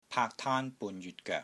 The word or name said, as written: PAAK3 TAAN1 BUN3 JYUT6 GOEK3